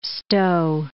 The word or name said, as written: stow